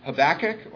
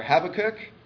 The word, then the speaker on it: Habakkuk: Next week, we're going to be in Habakkuk or Habakkuk. We're not going to fight over that, the pronunciation.